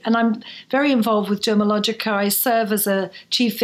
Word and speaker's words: Dermalogica: And then, of course, you know, when you realize that people are talking to you, you say Dermalogica and they don't say, oh, Dermatologica, you know, which is a common mispronunciation. I'd say, no, the T is silent, actually.